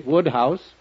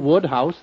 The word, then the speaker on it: Wodehouse: Woodhouse. – What was that again? – Woodhouse, that's how it's pronounced.